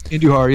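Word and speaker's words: Andújar: Andujar, yeah.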